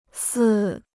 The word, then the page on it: 四: sì